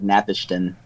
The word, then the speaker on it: Napishtim: I'm sure people knew before, but I didn't find out until Ark of Napishtim. – Fantastic name that the East franchise loves to use. – At least it can kind of be done phonetically, because you're like Nap-ish-tim.